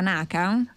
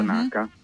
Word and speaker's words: Naka: Ho detto bene, la Naka? – Esatto, uh-huh. Naka.